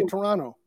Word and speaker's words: Toronto: You don't pronounce the T. – Oh. – Right. You say Toronto. – Toronto. – There's no T in it. There's only one T in Toronto.